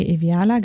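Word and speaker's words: Iweala: Gnosi Okonjo Iviela. Ähm, nee, Iviala, genau, ähm, ist wieder im Gespräch.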